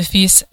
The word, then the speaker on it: FIS: But the acronym is the FIS, le FIS, which is how you say it in French, and that's the same, it's a homonym for the word son.